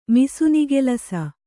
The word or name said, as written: ಮಿಸುನಿಗೆಲಸ